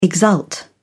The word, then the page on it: exult: British English